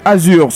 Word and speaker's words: azur: Le R final de Azur se prononce-t-il ou pas ? – Ça se prononce.